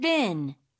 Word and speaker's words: been: Been, B-E-E-N. – 그렇죠. been이 아니고 been. 이렇게 좀 짧게 발음하고요.